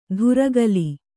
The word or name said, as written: ಧುರಗಲಿ